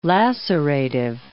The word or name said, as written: lacerative